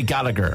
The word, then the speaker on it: Gallagher: No, it's a UK thing, they pronounce the G. Like, so they would always say Gallagher.